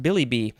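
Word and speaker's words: billi-bi: I think it's pronounced Billy B. B-I-L-L-I-B-I. And it looks like there's a hyphen before the last B-I. And then it could also be spelled B-I-L-L-Y hyphen B-I. This is a noun from 1961.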